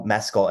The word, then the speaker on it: Mescal: Well, we're all very excited to see the Paul Mescal. And hey, we both said it right this time.